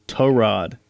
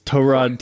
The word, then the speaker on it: Tyrod: Uh, so Tyrod. – Uh, Tyrod Taylor, yeah. – Hey so, man, didn't you watch? I, didn't you watch Hard Knocks? It's Tyrod. – Is it really? – Yeah, it's Torod Taylor.